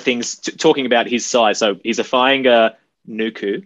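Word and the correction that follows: Fainga'anuku: Did I butcher the name again, Nels? I did, didn't I? – Fainga-Nuku. – Fainga-Nuku. Yeah, I can't get that extra syllable in there.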